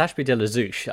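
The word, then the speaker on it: Ashby de la Zouch: And I felt lightly compelled to tell you that I grew up in the oddly named town of Ashby de la Touche. What? Is that how you say it? How do you say it? – Well, it's Ashby de la Zouche. I think there's a typo in this.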